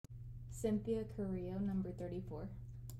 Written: Cynthia Carrillo

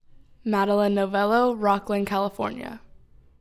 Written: Madilyn Novello

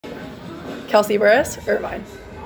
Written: Kelsey Burrus

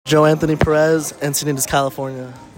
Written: Joe Anthony Perez

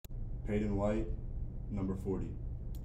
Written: Payden White

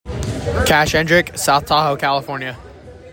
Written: Kash Hendrick

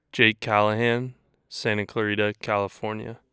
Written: Jake Callahan

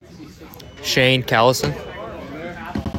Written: Shane Callison

